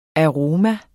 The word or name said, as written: aroma